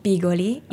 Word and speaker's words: bigoli: Bigoli? – Bigoli in salsa.